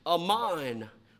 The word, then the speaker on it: aman: That word believe from the Hebrew context is pronounced aman, which is where we get our word amen, which means to be firm or sure of.